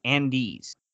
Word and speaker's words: Andes: But they changed the name to just A-N-D-E-S, Andy's, right? – Okay, I've got it.